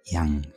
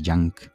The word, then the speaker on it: Yang: Con una J casi muda, una apreciable extensión sobre la A y que la conjunta con la N y una G que sería mejor pronunciar como una C muy suave. Al principio se obligaba a tener cuidado de no llamarle Yank,